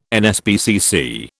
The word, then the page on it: NSPCC: British English